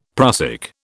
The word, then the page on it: prussic: British English